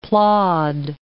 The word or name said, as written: plod